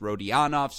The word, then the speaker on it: Rodionov: Is it Rodionov? He didn't have the pronunciation on his ATP site. I defer to the podfather, Mike Cation, who inferred that it was Rodionov, so that's what we're going to go with on this podcast.